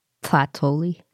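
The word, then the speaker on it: tlahtolli: Yeah, you got to like push the air. It's like you don't really pronounce the T. It's more like tlatoli. – The way they describe it was like Sid in Ice Age, and the way he sort of has a lisp.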